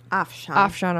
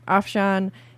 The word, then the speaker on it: Afshan: And then they had Chris Rankin, Afshan. Did I say that right? – Afshan. – I'm so sorry. Afshan.